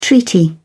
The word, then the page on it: treaty: British English